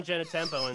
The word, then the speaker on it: Genitempo: And did I just say his name wrong, by the way? – Yeah, you said it like maybe one of our coaches used to say. No. Dr. Alan Genitempo and Dr. Nick Genitempo.